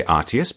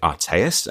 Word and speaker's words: Artaius: So people from the north of the country tend to say Artius. People from the south say Artaeus, and everyone else just says James.